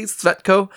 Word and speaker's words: Tsvetko: I believe that's pronounced correctly. Tsvetko. I looked up a couple pronunciations and that's kind of what I could find.